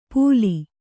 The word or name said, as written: ಪೂಲಿ